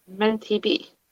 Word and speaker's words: Menty-B: M-E-N-T-Y dash B. – Menti B.